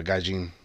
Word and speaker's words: Gaijin: I didn't know if you'd pronounce the J or not. Gaijin. – You do.